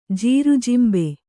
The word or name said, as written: ಜೀರುಜಿಂಬೆ